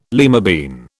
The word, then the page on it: lima bean: British English